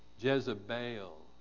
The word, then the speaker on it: Jezebel: Stretch out that last syllable a little bit. Jezebel.